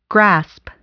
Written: grasp

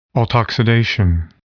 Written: autoxidation

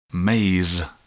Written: maize

